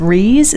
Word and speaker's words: RIIZE: I will say SHINee's performance on the MMA, and I think it's Reeze, is that how they say it? – Yeah. – R-I-I-Z-E.